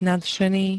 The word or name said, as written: nadšený